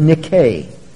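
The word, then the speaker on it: Nike (νίκη): Incidentally, that's the Greek word nikos, which sometimes appears in the New Testament, nike, spelled in Greek letters N-I-K-E, which we would pronounce Nike.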